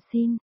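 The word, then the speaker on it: xin (心): Một, đọc là y, trái tim, đọc là xin.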